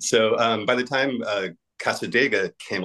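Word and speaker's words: Cassadaga: By the time Casadega came out, which I always want to call Casadaga because that's kind of how it's spelled. But on the record, on one of the samples, they say Casadega. So, and that really, it sounds better.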